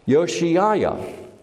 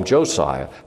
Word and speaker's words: Josiah: So looking during the reign of King Josiah, his name is pronounced Yoshiah. Yoshiah, because the Germanic J is pronounced with a Y, but we carried it over to J, so we call him Josiah.